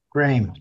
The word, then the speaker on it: Graeme: So it's pronounced Graeme. It's like frame with a G. – Graeme.